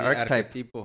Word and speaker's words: arquetipo: Ar arcotipo, ¿cómo se dice? arquetipo. Arquetipo.